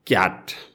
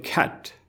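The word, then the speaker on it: cat: अङ्ग्रेजीमा क्याट भने त नेपाली उच्चारण हो अङ्ग्रेजीमा भन्नुभयो भने ख्याट त्यो सुरुमा सुरुमा आउँदा क हुँदैन ख भन्नुपर्छ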